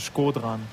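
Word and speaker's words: Shkodran: Ja, mein Vorname ist nicht so ganz einfach, aber mein Vorname wird ausgesprochen Skodran.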